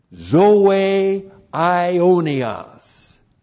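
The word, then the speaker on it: Zoe Ionios (ζωὴ αἰώνιος): For our interest and our benefit, what God the Father has given for our interest and our benefit is Zoe Ionios. Zoe, Z, long O, long E, Ionios, A, I, long O, N, I, O, S, Zoe Ionios, eternal life.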